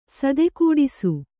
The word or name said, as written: ಸೇದೆಗೆಡಿಸು